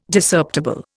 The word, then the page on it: Discerptible: Indian